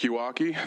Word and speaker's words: Yawakie: Hello, my name is Patrick Yawaki. That's spelled Y-A-W-A-K-I-E.